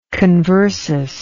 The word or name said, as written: conversus